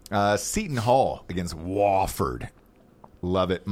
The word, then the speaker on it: Wofford: I'm going Wofford.